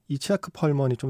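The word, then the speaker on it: Itzhak Perlman: 오늘은 이작 펄먼이라고도 하고요. 음, 원래 어, 발음에는 이치아크 펄먼이 좀 더 가깝다는 얘기를 들었습니다.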